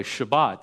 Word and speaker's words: Shabbat: We just borrowed the word because the Hebrew word is Shabbat. Maybe you've heard it pronounced that way, Shabbat. And I kind of like saying Shabbat better than I do Sabbath anyway.